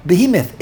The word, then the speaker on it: Behemoth: In English, how do you pronounce it in English again? – Behemoth. – Behemoth.